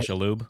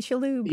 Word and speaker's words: Shalhoub: Tony Shalhoub. – Tony Shalhoub.